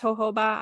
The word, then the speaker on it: jojoba: So it's actually spelled jojoba. It took me a while to figure out that it's pronounced jojoba.